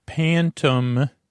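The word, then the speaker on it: Phantom: This one is called the Pantom. But pronounced with an F, rising card.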